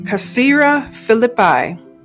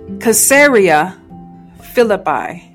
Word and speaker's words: Caesarea Philippi: Then Jesus and his disciples went out to the villages of Caesarea Philippi. And on the way, I know I said that wrong. Caesarea Philippi. Thank you.